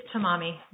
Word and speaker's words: Tamami: It's Tamami. Very close. Thank you. Good, Good morning. May it please the Court. I'm Francesca Tamami, counsel for the United States.